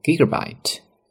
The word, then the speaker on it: gigabyte: 32，注意 GB 怎么读呢，它是英文单词 gigabyte 的一个缩写，那么就是 include 32 gigabyte of storage， 32G 的一个内存。 The mid-tier model，那么再往上中档的手机的内存呢 has 128GB 128G and the top tier packs 256GB of storage，内存最高的手机呢是 256G。那么内存这个词得知道 storage，其次呢 GB 读作 gigabyte， 32GB of storage， 32G 的内存。